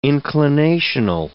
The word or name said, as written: inclinational